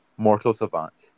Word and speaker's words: Mortal Savant: I want to say Mercy Servants, if I say his name right? – Mortal Savant. – Mortal Savant? – Yeah.